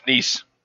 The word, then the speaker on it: Nice: But anyway, on August 5th, Madonna ended her Blonde Ambition tour in Nice, France. – Nope. – It's Nice. – Nope. – Right? – Nope. – Nice. Pronounced Nice.